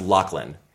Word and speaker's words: Loughlin: And then that got to Lori Loughlin, which actually, I believe it's pronounced Loughlin.